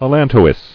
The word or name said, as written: Allantoic